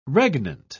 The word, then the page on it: regnant: British English